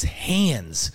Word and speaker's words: Hans: We were doing preseason interviews with the football team, and, and I was like, "Is it Hans? Is it Hans? Like how do you say?" it? And he goes, "Just remember." And he put his hands like this, and he goes, "It's hands, like hands."